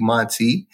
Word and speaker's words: Monte: You can easily reach me on social media, Facebook and Instagram, by searching my name at MC Monte.